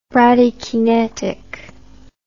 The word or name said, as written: bradykinetic